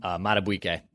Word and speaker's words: Madubuike: I can't pronounce his name. – Madubuike.